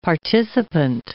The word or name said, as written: participant